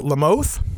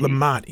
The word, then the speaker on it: Lamont: Is it LaMothe? – It's Lamont, actually, with a hard T. – Lamont.